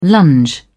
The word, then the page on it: lunge: British English